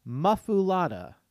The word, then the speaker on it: muffuletta: It says, M-U-F-F-U-L-E-T-T-A is pronounced muffulata. Muffulata.